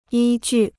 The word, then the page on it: 依据: yī jù